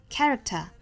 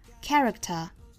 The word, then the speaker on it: character: 读成 person, character, determined, 和 rather。好我们来看。 A person's character, Character 这个单词我们注意里面的这个 k 和 t 放在一起是两个爆破音哈，所以我们这个 k 是把它做失去爆破 Character Character 单词内部的失去爆破不要读成了 character 应该是 character